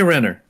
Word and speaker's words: Renner: We all know, everybody knows that Reiner? How do you pronounce his name? Jeremy Renner.